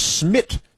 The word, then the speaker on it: Smit: The Indian pilot, whose first name is Smit, like Smith, and his last name is more difficult because it's Menchachar, but it's M-A-C-H-C-H-H-A-R.